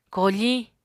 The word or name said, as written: koofiñ